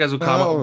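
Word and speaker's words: Ezukanma: Eric Ezukama. And by the way, that's how you pronounce it, because I just heard him pronounce his name. – Yeah. – Eric Ezukama, by the way, who's a talker, seems like a great kid.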